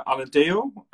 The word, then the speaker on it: Alentejo: So uh, Alentejo.